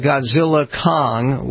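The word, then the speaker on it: Godzilla x Kong: This is called Godzilla Kong with an X in between, which you don't pronounce, I guess.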